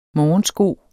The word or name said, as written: morgensko